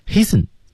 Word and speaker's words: hasten: Do not 失去爆破，失去爆破，注意 Do not 没有这个音， hasten 中的 t 也是不发音的，大家注意。然后 to be 也是不发音的。